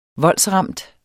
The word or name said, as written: voldsramt